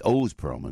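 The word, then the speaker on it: Oz Pearlman: And by the way, I want to tell you, too, Oz Perlman, and Oz Perlman is the way you pronounce his name.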